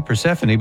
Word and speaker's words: Persephone: It's like, Stephanie, Persephone, but it's spelled totally different. – Right. – It's purse phone.